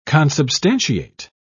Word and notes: consubstantiate: British English

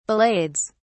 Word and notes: ballades: British English

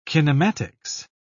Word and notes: kinematics: British English

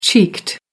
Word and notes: cheeked: American English

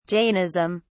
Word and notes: Jainism: British English

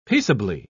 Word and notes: peaceably: British English

